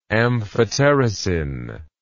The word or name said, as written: amphotericin